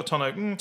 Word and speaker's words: tonneau: I never know how to pronounce this word. Is it a tonneau roof? A tonneau. Tonneau? – Yeah, it's got a tonneau.